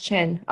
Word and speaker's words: Qin: Emperor Qin, pronounced Qin.